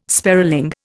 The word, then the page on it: sparling: Indian